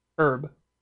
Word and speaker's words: herb: Herb. – Basically, yeah, just drop the H. – Yeah. – Because the H is silent.